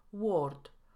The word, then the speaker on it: word: Długie szwa występuje w słowach word, world, work. My Polacy najczęściej wrzucamy tutaj samogłoskę o i mówimy word, world, work.